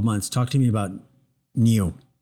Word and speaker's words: NIU: Well, I think the company is pronounced NIU. It's N-I-U, NIU Technologies.